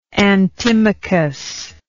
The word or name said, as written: antimachus